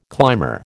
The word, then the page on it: climber: American English